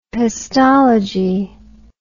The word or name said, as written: pestology